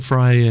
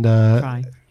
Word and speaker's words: Frey: And then Glenn Frey and Fry.